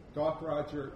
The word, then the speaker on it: Gaukroger: Stephen Gaukroger is how you pronounce it.